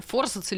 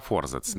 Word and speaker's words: форзац: Ну, форзац или форзац? – А, пока форзац, да. Пока форзац, но направление движения в сторону ударения форзац.